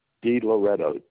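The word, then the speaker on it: DiLoretto: D Loretto, D-I capital Loretto.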